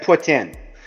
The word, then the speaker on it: poutine: And we've been saying poutine wrong the entire time, folks. – How do you say it? – Well, see, I say it just because it's how I was like, just because it's that's how you say it up here. But yeah, it said put in, and you kind of put that. I guess you put that little that French Canadian accent on it a little bit when you say it.